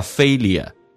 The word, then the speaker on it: failure: 那么下面一个单词呢，英式发音叫 failure，美式发音叫 failure，那个 L 提前发 failure，所以我们以前把 L 放后面，那么美式发音放前面，这有可能有人听不懂这个美式发音叫 failure，失败。